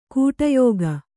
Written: ಖೋಡಿಯೋಗು

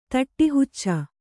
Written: ತಟ್ಟಿಹುಚ್ಚ

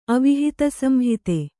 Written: ಅವಿಹಿತಸಂಹಿತೆ

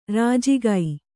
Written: ರಾಜಿಕೆ

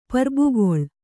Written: ಪರ್ಬುಗೊಳ್

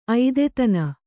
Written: ಐದೆತನ

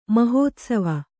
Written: ಮಹೋತ್ಸವ

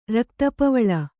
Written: ರಕ್ತಪವಳ

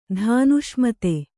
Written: ಧಾನುಷ್ಮತೆ

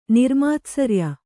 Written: ನಿರ್ಮಾತ್ಸರ್ಯ

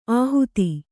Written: ಆಹುತಿ

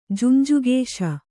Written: ಜುಂಜುಕೇಶ

